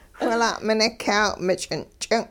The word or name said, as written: whila'minik'yaaw-mich'in-ch'ing'